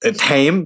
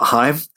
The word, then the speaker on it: Haim: And it's Haim, not Haim.